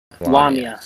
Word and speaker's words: Lamia: Lamia is the correct pronunciation.